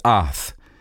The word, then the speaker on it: oth: Another word that is used for miracle in the Old Testament was the Hebrew word oth. O-T-H is the way we pronounce it.